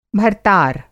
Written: भर्तार

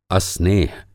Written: अस्नेह

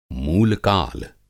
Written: मूलकाल

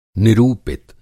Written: निरूपित

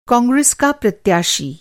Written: प्रत्याशी

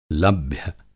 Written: लभ्य

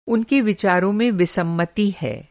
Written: विसम्मति

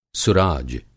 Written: सुराज

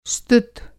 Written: स्तुत

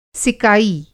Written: सिकाई

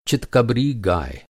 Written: चितकबरा

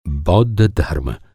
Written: बौद्ध

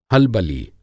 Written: हलबली

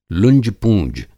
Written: लुंजपूँज

